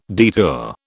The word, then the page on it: detour: British English